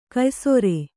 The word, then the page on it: ಕೆಯ್ಸೇರ್: keysēr